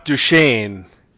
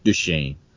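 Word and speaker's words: Duquesne: It is Jacques Duchesne. – Jacques Duchesne, darling, Jacques Duchesne. – I don't know if I'm pronouncing that right. It's D-U-Q-U-E-S-N-E. – That sounds Duchesne.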